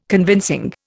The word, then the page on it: convincing: Indian